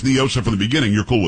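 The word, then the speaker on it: NIOSA: Do not, because it's definitely Nyosa. It is not a Spanish word. What does Nyosa mean in Spanish? Nothing. – I don't know. – Well, that's the whole argument. They try to pronounce it like it's Spanish. It's not.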